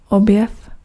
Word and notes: découverte: [de.ku.vɛʁt]; France

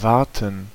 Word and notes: wachten: /ˈʋɑx.tə(n)/

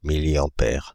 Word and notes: milliampère: Paris; [mi.li.ɑ̃.pɛʁ]

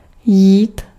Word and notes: interest: English

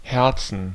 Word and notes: Herzen: /ˈhɛʁtsn̩/

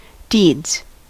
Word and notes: deeds: American English; [diːdz]; English

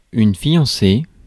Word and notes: fiancée: France; [fijɑ̃.se]

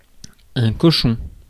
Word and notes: cochon: [kɔ.ʃɔ̃]; France

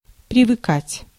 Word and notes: привыкать: /prʲɪvɨˈkatʲ/; privykat

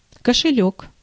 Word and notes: кошелёк: /kəʂɨˈlʲɵk/; košeljok

